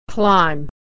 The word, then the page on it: climb: /ay/; American English